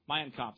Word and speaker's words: Mein Kampf: His version of Darwinism was incorporated in Adolf Hitler's Mein Kampf. Did I say that right? Mein Kampf, thank you.